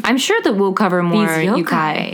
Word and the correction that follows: yokai: Yokai. – Damn it. – All right, dude. Now you got to say it like that for the rest of your life. – Try it now. It's yokai. And you know what sucks is that I thought it was yokai, but then in the phonetic spelling, it's spelled YOU/KI.